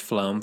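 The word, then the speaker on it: Flåm: You took the Flom Railway. – It's pronounced Flom for those of us in the Norwegian know.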